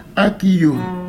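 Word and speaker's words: Akiyo: Aquillot.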